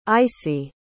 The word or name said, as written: icey